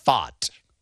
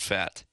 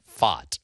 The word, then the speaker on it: Pfaadt: If you're watching Fox and you see the letters PFAADT come up on your television, the correct pronunciation is Fott. – It's not fat. – No. And he is not fat. – That's right. – It is Fott.